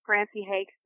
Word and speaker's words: Francie Hakes: It's just like Francie Hakes bakes cakes.